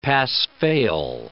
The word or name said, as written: pass-fail